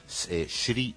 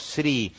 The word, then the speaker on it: Sri: En realidad nosotros decimos Sri, pero en regiones de la India y de Sri Lanka, esa S es una sh. Lo que pasa es que para nosotros es bastante bastante difícil pronunciarlo, por eso decimos Sri Lanka en vez de Sri Lanka, como ellos dicen.